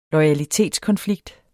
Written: loyalitetskonflikt

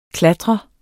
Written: klatrer

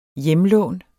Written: hjemlån

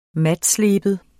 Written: matsleben

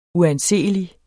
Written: uanseelig

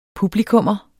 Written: publikummer

